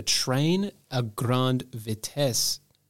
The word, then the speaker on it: Train à Grande Vitesse: TGV standing for Train à Grande Vitesse.